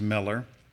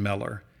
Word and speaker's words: Mellor: My last name is Miller. It's spelled a little bit oddly. My last name is M-E-L-L-O-R, Nathan Miller.